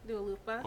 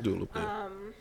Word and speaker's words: Dua Lipa: So I like Dua Lipa. I always get her name wrong. – No, you're right. – Dua Lipa. – Dua Lipa.